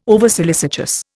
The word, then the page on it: oversolicitous: Indian